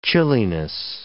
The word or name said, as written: chilliness